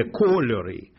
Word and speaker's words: кольори: І, до речі, зверни увагу, Олексій, «два кольори мої», наголос іде «кольори».